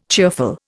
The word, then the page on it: cheerful: Indian